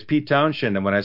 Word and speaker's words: Townshend: When you say Shen, you don't add an H. It's just, you pronounce it like Townshend, you know.